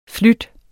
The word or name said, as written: flydende